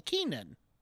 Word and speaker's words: Keiynan: Keenan. – Is it just Keenan? Lunt? – Keenan? – Keenan. Keenan? Keenan. Oh, okay. Keenan.